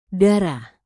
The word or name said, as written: darah